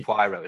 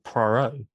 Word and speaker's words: Poirot: So this is the Detective Poirot, is that right? – Hercule Poirot.